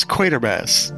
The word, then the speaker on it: Quatermass: I would like to commend you on your pronunciation, Doctor Head, because so many people say Quatermass.